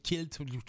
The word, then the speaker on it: Quilt: On prononce ça comme ça, Quilt? – Oui, absolument. Ah, c'est la bonne prononciation, oui. – Parce que ça aurait pu être Quilt,